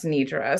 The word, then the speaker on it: Nedra: It's D-E-D-R-A. – Yeah, bestie, my name is Nedra, and it's pronounced Nedra. So, like, long E's exist.